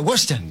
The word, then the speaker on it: Worcester: Oh, in Worcester? – Yeah, that's it, Worcester. That's how you say it.